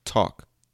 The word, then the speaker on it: talk: Another example is the letter L in the word talk. T-A-L-K. T-A-L-K. Talk. We don't say talk because that's the way it should be pronounced if you read it the way, I mean, if you say it the way it's written, right? Talk. But we say talk. Okay. Another example is the letter B in lamb. L-A-M-B.